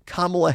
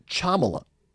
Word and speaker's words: Kamala: And then they started pronouncing Kamala Harris's name. And they pronounced it Chamala, a C-H.